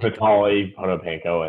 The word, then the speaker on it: Vitali Potapenko: Is it Potapenko? Does he have a special pronunciation? – I don't think so. I think that's how it is. – Oh, Vitali Potapenko. – Vitali Potapenko. I think pretty sure that's it.